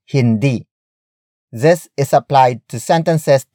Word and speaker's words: hindi: First, we have saan, which is spelled as S-A-A-N. It means where, followed by hindi. Hindi. Hindi. Hindi is spelled as H-I-N-D-I.